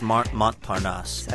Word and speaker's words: Montparnasse: Montparnasse Metro? Is that right in Paris? – Montparnasse. – I've been there. I don't know if I can say. – You say the S? Montparnasse.